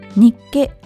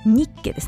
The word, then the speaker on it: ニッケ: インナーファクト、ニッケアクシオウールソックスです。あ、イントネーション間違えましたね。ニッケですね。